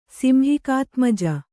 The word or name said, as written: ಸಿಂಹಿಕಾತ್ಮಜ